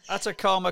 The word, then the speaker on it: Atacama: So if I get the pronunciation right on this, tell us about the Atacama. Is that how you say it? Atacama. – Atacama crossing in Chile in the desert.